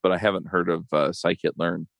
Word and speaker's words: scikit-learn: So you wrote this blog post about Scikit Learn. Am I saying that right? – Scikit Learn, yes.